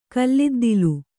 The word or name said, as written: ಕಲ್ಲಿದ್ದಿಲು